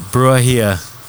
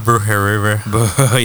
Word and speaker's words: Brujeria: Brujeria. Yeah, there you go. That's how you say it. Not Brujeriver.